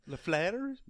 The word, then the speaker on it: LaFleur: That's how I pronounce it, if you want the correct French-based pronunciation. – LaFleur?